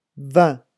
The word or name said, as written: vin